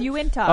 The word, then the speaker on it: Uintah: It's Uintah. Oh, that's how that's pronounced.